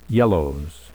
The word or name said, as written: yellows